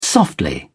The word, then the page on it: softly: British English